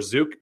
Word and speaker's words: Zook: And so now I'm Jason Zook or Zook. It's totally cool if the Canadians say Zook because that is phonetically how it probably should be pronounced.